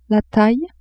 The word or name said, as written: taille